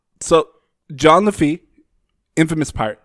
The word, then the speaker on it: Lafitte: It was first structured in the 1770s by the pirate known as John Lafayette. Did I pronounce that right, Moses? I know you had it down. – It's Lafitte.